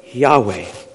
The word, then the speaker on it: Yahweh: The four Hebrew consonants, often pronounced Yahweh.